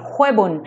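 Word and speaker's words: Xwebûn: Se escreve com é, X. X-W-E-B-U-N. Huebun. É nem tinha, nem tinha visto mutado. Escreve X-W-E-B-U-N. Huebun. É porque o X na, em idioma curdo é re.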